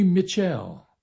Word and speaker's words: Mitchelle: Erskine Hawkins, big band, did the version, vocal by his alto saxophone player and ballad vocalist, Jimmy Mitchell. Why do I pronounce it that way? Because the spelling leads me to believe, M-I-T-C-H-E-L-L-E. So I guess he didn't want to be called Mitchell.